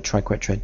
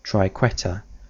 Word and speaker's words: triquetra: English speakers often find the word triquetra difficult to pronounce, and so one very often hears it pronounced with the U and or the second R made silent. In other words, they pronounce triquetra or triquetra.